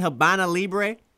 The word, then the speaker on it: Habana Libre: Am I saying that right, Habana Libre? – You are saying it correct.